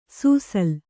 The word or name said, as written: ಸುಸಿಲು